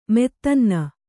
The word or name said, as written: ಮೆತ್ತನ್ನ